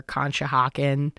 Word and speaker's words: Conshohocken: Like the Schuylkill or Conshohocken or Wissahickon. Those I'm pretty sure I'm saying right.